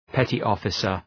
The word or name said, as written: petty officer